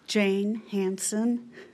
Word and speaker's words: Jane Hansen: Jane Hansen, J A N E H A N S E N.